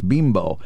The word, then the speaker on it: Bimbo: Bimbo is the correct pronunciation? – That's what I was told. Okay. I mean, I always called it Bimbo Bakeries, but someone said to me, no, it's Bimbo.